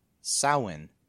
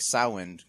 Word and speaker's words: Samhain: The standard Irish pronunciation of it is Samhain. There's no M pronouncing. They're pronouncing it as like Samhain, kind of like the ow and cow.